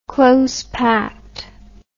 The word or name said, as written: close-packed